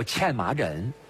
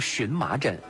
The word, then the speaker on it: 荨麻疹: 嗯，这最常见到的一个皮肤病，把它叫茜麻疹。嗯，今天大家也把它读成荨麻疹。